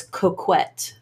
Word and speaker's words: coquette: It's, oh, sorry, it's coquette. – Oh, coquette. – Q-U-E-T-T-E. Sorry. Okay. I didn't pronounce it French enough for you.